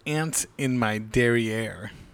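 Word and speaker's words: derrière: D E R R I, E with the little thing on top, R E, derriere.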